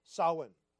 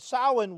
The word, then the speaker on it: Samhain: And chief among these festivals was what they called the Fire Festival, or Samhain. All right, it's spelled actually S-A-M-H-A-I-N.